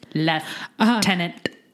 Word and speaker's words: lieutenant: Most English, speaking, most English speaking nations, with the exception of the United States, still pronounce the word as though there is an F in it. As in lieutenant.